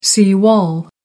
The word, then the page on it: sea wall: American English